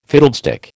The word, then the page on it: fiddlestick: American English